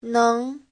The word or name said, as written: neng2